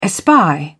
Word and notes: espy: British English

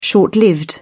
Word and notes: short-lived: British English

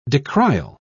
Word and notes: decrial: British English

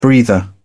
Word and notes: breather: British English

